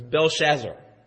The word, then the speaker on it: Belshazzar: It's Belshazzar.